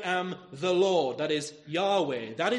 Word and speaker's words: Yahweh: That's the name which is used to translate this special name of God, YHWH, and it's a name which was most likely pronounced like this, Yahweh.